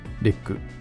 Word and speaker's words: REC: えー、 REC と書いて、レック、もしくはレック 2、えー、ですね。